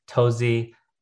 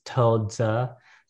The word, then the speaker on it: Touzi: How well, I say tozi. So that's how people should probably say it. But in the correct pronunciation in Mandarin, which I don't speak very well, it's toza. And I will never say like that, but it's tozi basically.